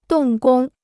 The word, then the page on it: 动工: dòng gōng